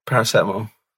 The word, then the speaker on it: paracetamol: You keep pronouncing stuff wrong. You've just said paracetamol is paracetamol. – What's the definitive on this, please? – Paracetamol. – Thank you. You've answered that correctly.